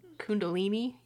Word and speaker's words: Kundalini: I'm going to do my best to pronounce this. I even looked up how to pronounce this and I couldn't really figure it out, but it's Kundalini. – That's yoga. – It. – Okay. – Yeah, you got it.